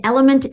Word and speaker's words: LMNT: And element is spelled L-M-N-T. There's no E's there.